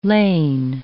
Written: lain

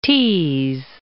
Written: Tees

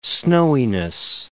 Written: snowiness

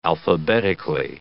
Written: alphabetically

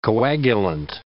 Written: coagulant

